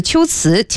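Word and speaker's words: 龟兹: 词这个秋瓷兰啊引发了热烈的讨论啊。那本月的 17号到19号，舞剧这个秋瓷将在保利剧院上演。 – 嗯，它那个秋字就是乌龟的龟，它是个多音字啊。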